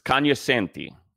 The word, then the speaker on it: cognoscenti: It's cognoscenti. The G is silent.